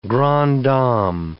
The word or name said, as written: grande dame